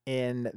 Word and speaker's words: Ai: I believe the actual pronunciation is something more like I, but I'm going to call it Ai just so we can understand what I'm speaking of.